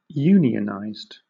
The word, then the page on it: unionized: British English